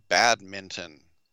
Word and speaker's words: badminton: My question is this a game about a haunted house, or is this a game about badminton? That's right, I hit the N hard there. I pronounced it right.